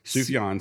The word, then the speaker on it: Sufjan: Sufjan Stevens.